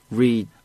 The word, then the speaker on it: read: Read. – Read. – Read. – Read. – Read. – Read. – Read. – 우 발음으로 시작하라 그랬습니다. R은요.